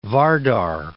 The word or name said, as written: Vardar